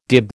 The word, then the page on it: dib: American English